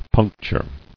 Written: Puncturing

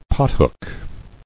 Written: Pothook

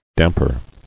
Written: Damper

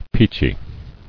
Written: Peachy

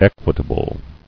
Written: Equitably

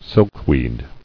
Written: Silkweed

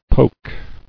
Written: Poked

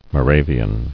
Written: Moravian

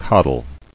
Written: Coddled

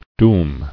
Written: Dooming

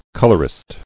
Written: Colorist

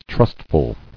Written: Trustful

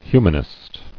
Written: Humanistic

